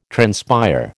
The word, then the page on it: transpire: American English